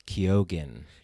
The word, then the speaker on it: Keoghan: It's Keoghan.